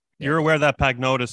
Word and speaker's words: Pagnotta: So his last name is Pagnotta. You don't pronounce the G in Italian.